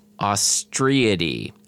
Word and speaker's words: Ostreidae: Many, but not all, are in the family, how would I pronounce this? – Ostreidae. – Ostreidae. Okay.